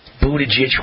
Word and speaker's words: Buttigieg: It's Buttigieg. Is that how you pronounce it? Pete Buttigieg, is that the real way? Buttigieg. Okay. You say for the 900th time, but I can't pronounce it.